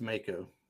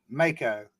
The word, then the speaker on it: Mako: It's got a, is it Mako or Mako? He's the wizard. – I've always heard it pronounced Mako. – Mako, like the shark.